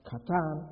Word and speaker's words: qatan: The adjective where it says lesser actually comes from a Hebrew word that is either pronounced katan or katon. Q-A-T-A-N, katan, or katan.